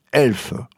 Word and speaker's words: ELF: Comme personne ici ne peut laisser un E muet, cela se prononce ELF et ombelle.